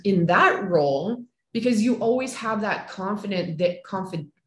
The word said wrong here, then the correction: confidant: how do you say it, confidant there,